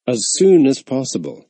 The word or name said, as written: as soon as possible